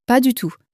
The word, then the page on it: pas du tout: french